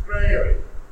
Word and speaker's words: Freire: I always want to say free air, and I know it's not right. And I asked somebody the other day, how do you pronounce that correctly? Because I'm in Brazil. Freire.